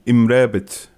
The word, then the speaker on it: Amrabat: Amrabat. Sofyan Amrabat. – Roll the R.